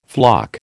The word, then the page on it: floc: American English